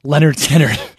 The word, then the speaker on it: Lynyrd Skynyrd: Debut album is actually titled Leonard Skinner, pronounced Leonard Skinner. – Right. – But the crazy thing about it is it's spelled L-Y-N-Y-R-D, S-K-Y-N-Y-R-D.